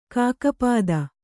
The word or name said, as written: ಖಗಪತಿ